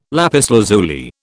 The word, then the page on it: lapis lazuli: British English